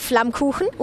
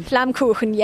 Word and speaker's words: Flammkuchen: Oh yeah, we have Flammkuchen, which is... – Say that again! – Flammkuchen. – And wait, spell that. – Yeah, it translates to flame cake. So Flammkuchen. – Flammkuchen. – Flammkuchen, yes.